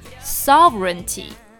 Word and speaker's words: sovereignty: 好，首先我们讲主权这个单词是 sovereignty。那我们单看这个单词的发音和它的拼写呢，确实好像不是那么吻合，所以大家要花一点时间去记忆一下哦。 Sovereignty。好，那我们说国家主权，前面要加一个 State Sovereignty。